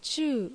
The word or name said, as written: Chuu